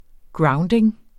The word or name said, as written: grounding